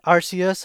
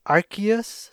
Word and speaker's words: Arceus: I believe it's pronounced Arceus. I've also seen Arceus or Arceus, but I believe there's an official Pokemon company answer as to how it should be pronounced, and it's Arceus, so I'm gonna go with that.